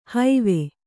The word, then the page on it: ಹೈವೆ: haive